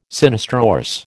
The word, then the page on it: sinistrorse: American English